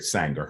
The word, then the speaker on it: Sanger: Well, I, I, encourage people to uh, do their own research on Margaret Sanger. Her name is spelled just like danger, but with an S.